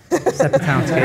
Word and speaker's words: Sepetowski: So my name's Alex Sepetowski. You kind of say it like spare house key, Sepetowski.